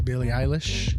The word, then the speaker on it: Billie Eilish: Billie Eilish.